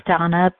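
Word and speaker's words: Dana: This is Donna B.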